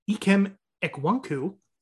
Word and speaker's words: Ikem Ekwonu: And then they drafted Ikem Ekwonu from, I probably butchered that. Ikem Ekwonu, thank you, is from NC State, who's a stud.